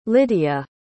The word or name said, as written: lidiya